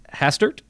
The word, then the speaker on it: Hastert: Brandon has interned on Capitol Hill in the office of former United States House Speaker Dennis Hastert.